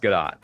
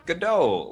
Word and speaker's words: Gadot: Is it Gadot? – It's pronounced Gadot. Yeah, Gal Gadot. – Dang it. – Mm-hmm. Why? What did you think it was? – Godot, like the Sam Beckett play. Waiting for Gal Gadot.